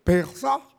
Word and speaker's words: Pesaj: La Pascua era una fiesta romana, donde se celebraba las primicias del campo, porque como no había traducción en el latín para Pesa, que así se pronuncia, Pesa,